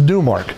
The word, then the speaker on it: Domark: I found someone online who said it is Dumark.